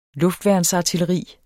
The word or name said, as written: luftværnsartilleri